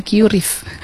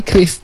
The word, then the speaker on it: QRIS: Dibacanya itu kris, jadi orang BI, kalau nggak salah itu pernah menjelaskan, dibacanya itu kris, ya, bukan QRIS, tapi kris.